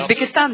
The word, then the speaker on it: Узбекистан: Is that how you pronounce it? – Узбекистан, да, так оно говорится.